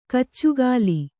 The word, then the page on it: ಕೂಚುಗೋಲು: kūcugōlu